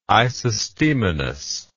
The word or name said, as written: isostemonous